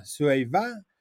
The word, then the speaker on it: Cuiabá: El Cui, así se escribe, C-U-I-A-B y con el acento en la A. Cuiabá, Cuiabá, pero bueno, por ahí va.